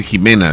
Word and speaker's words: Ximena: En algunos nombres la X se pronuncia como J, como Texas, dice, okay. Se dice Texas. Como México, se escribe México, se dice México. México. Probablemente ella sea decir Ximena, dice, probablemente.